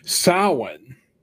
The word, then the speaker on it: Samhain: In English, they say Samhain, but the actual word in Irish is pronounced Samhain. – Samhain.